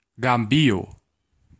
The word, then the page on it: Gambia: [ˈɡæm.bi.ə]